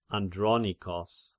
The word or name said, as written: Andronikos